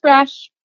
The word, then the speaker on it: Rasch: Just Rasch.